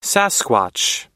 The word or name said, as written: sasquatch